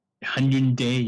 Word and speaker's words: Hyundai: And that is a natural accident called Hyundai. Did I say that right? – Hyundai, yeah.